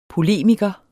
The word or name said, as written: polemiker